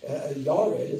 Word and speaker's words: Yared: And then Mahalalel's son, Yared, as it's pronounced in the Hebrew, but Jared, as we see, means decline.